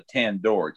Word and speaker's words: Tandor: For the record, in Pellucidar, a mammoth is a Tandor, T-A-N-D-O-R. It's pronounced similar to Tantor.